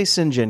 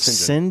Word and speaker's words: St. John: And it's a common pronunciation for St. John is Sinjin. – Sinjin? – I think it's a Brit thing. It's a British pronunciation.